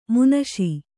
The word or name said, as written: ಮಾನುಷ